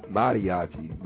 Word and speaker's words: mariachi: It's Mary Mariachi. Mari. How the hell? Yeah, mariachi is spelled M-A like Maria Chai. M-A-R-I-A-C-H-I. Mariachi. There you go, people.